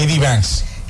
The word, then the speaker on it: J.D. Vance: J.D. Vance.